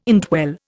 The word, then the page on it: indwell: Indian